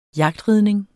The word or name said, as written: jagtridning